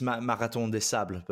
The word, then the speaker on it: Marathon des Sables: In French, it's Marathon des Sables, but most people call it the Marathon des Sables.